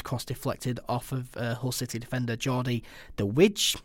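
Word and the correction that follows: de Wijs: No, it's Device, and I think it's... – Device? – Pronounced Device. – Jordi Device.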